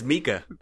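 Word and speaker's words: Miike: Directed by Takashi Mike? Mike? – It's Mika, similar to our Michelle.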